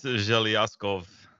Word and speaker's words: Zhelyazkov: It's Zhelyazkov.